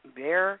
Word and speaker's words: Behar: Well, for all you people that watch The View, Joy Behar, I think as you pronounce her name, Bear, is leaving ABC.